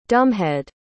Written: dumbhead

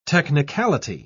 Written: technicality

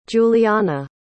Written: julianna

